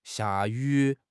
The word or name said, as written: xia4 yu3